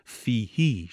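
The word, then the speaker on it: فيه: و اخیراً گفتیم حفص در یخلد فیهی مهانا و به طور خاری و ذلت در جهنم مخلد و جاودان می شود، کسره ها ضمیر در فیه را اشباع کرده به طوری که در تلفظ باید فیهی شنیده شود.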